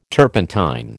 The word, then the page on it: turpentine: American English